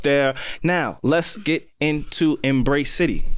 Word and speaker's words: Embracity: I just want to compliment you for saying the name right, because a lot of people say Embracity, and I'm come on. – How is it spelled? – E-M-B-R-A-C-I-T-Y. So it's embracing yourself within the city.